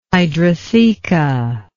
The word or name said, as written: hydrotheca